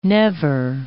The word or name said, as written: never